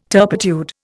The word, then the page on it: turpitude: Indian